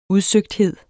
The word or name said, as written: udsøgthed